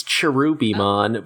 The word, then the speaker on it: Cherubimon: The actual Roman, the actual official romanization is Cherubimon, but it's just written Kirpimon because someone fucked up transliterating a while ago and it just sort of stuck.